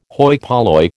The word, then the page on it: hoi polloi: American English